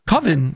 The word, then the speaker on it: coven: Richard George is the one that says, so he goes, how else would you pronounce it? And he says, Coven, because that's the correct pronunciation. And then Mark says, no, that sounds too much like oven, and that can't work.